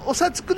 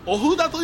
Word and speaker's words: お札: お札くださいバカモンお札と読むんじゃ